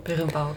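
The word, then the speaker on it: Berimbau: Das ist das Birimbau? – Birimbau, genau.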